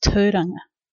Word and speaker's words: Tauranga: For those purists, I said Tauranga because it's supposed to be pronounced Tauranga, but I've always said it Tauranga, so that's how it is.